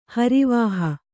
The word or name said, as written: ಹರಿವಾಹ